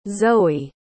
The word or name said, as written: zoë